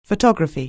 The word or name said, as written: photography